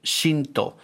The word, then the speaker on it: Xinto: This is a musician named Shinto Stevens, and it's spelled X-I-N-T-O, Shinto.